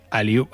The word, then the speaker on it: Aliun: Escribe Aliun, pero se pronuncia Aliu o Aliun.